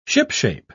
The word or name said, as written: shipshape